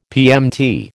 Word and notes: PMT: American English

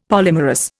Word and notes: polymerous: Indian

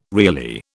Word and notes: Reilly: British English

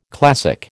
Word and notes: classic: American English

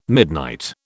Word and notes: midnight: British English

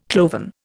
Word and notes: cloven: Indian

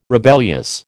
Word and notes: rebellious: American English

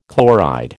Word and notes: chloride: American English

